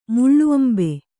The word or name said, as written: ಮುಳ್ಳುಅಂಬೆ